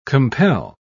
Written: compel

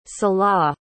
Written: salaah

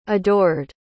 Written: adored

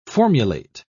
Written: formulate

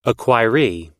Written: acquiree

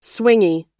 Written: swingy

